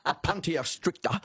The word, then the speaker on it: Opuntia stricta: Well, we could go back to 1788 because the first colonial settlers brought prickly pear cactus or opunt. I knew I'd say that wrong. Opuntia stricta.